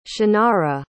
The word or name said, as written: chinara